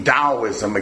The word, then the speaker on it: Taoism: So that's what Taoism, it's actually pronounced with a D, Taoism again in its original language.